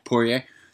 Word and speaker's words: Poirier: McGregor made his return to the octagon only to get beat by Dustin Poirier. Is that how you say it, Max? – Yeah, you got it. – Yeah, Poirier.